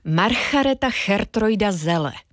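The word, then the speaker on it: Margaretha Geertruida Zelle: Margareta Chertroida Zelle. – A já, když dovolíte, všechny tyhle tři indicie propojím, ale nejdřív tě pochválím, vyslovila jsi to krásně.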